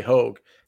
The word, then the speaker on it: Hoge: I mean, it might be pronounced Hoagie. I'm not even 100%. I always say Hoge. – Okay, we'll stick with Hoge. Hoge. I just wanted to let you guys know. I heard Jim Nance call him Tom Hoagie. Yeah. – He's probably right because Bo Hoge is H-O-A-G, and that's a hard G there.